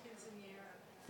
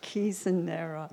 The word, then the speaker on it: Quinceañera: Quinceañera, is that how you pronounce that? – Quinceañera. – Thank you. Yeah. Quinceañera.